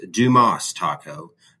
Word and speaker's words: Dumas: Dumas Taco is properly pronounced Dumas Taco, not Dumbass Taco.